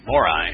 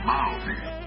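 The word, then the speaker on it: Māori: Maori.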